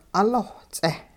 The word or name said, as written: 'uloh-ts'eh